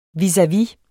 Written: vis-a-vis

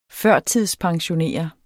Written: pensionskunde

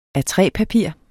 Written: A3-papir

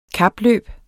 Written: kapløb

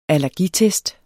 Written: allergitest

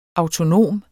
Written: autonom

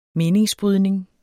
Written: meningsbrydning

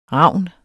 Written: ravn

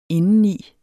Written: indeni

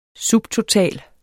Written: subtotal

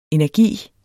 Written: energi

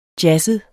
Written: jazzet

